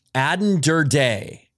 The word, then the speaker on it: Aden Durde: Aden Durde is uh, my understanding of the correct pronunciation.